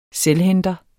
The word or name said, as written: selvhenter